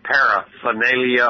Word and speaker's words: paraphernalia: P as in Paul, P-A-R-A, P-H-E-R-N-A-L-I-A. According to the wordsmith, that second R is optional on the pronunciation. You can say paraphernalia or paraphernalia. Emphasize that R.